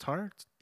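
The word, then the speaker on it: Tár: Like I said, I haven't seen Tar. I haven't seen... is it pronounced Tar? – Yeah, Tar, Cate Blanchett.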